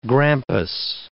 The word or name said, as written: grampus